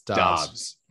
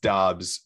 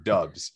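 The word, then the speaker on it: Doubs: It is pronounced Dobbs, but some of his friends call him Dubs, and so that got everybody all confused.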